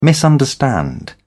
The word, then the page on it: misunderstand: British English